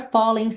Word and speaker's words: falling: You're a falling star. Falling, o som termina no N, o G não é pronunciado, por isso está pintadinho de cinza claro, certo?